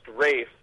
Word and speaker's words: Ralph: How come Ralph is pronounced Rafe, but Joseph is not pronounced Joseph?